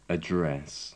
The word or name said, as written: address